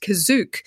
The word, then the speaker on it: Kashyyyk: And it features the original Star Wars cast and Han and Chewie trying to get home to Kashyyyk, which is here pronounced Kazook,